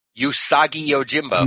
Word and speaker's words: Usagi Yojimbo: Let's see if I can pronounce this right. Yuji. How do you pronounce this? Yugi. – Usagi Yojimbo.